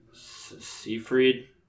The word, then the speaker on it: Seyfried: Is it Seyfried? – Seyfried.